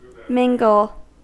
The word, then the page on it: mingle: American English